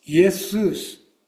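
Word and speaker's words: イ エ ス ウ ス (Ἰησοῦς): 少し名前の由来をお話ししたいと思うのですけれども、イエスという名前はギリシャ語ではイエスウスと発音します。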